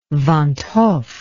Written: van't Hoff

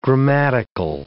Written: grammatical

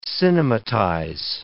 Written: cinematize

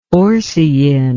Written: orcein

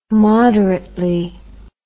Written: moderately

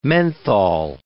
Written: menthol

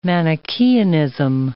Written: Manichaeanism

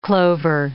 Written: clover

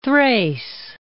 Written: Thrace

